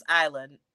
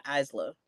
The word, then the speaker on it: Isla: But I am so happy for Alba Fyre and Isla Dawn, because Michael Cole kept mispronouncing Isla Dawn's name. It's like, it's Isla, not Isla. Please stop it.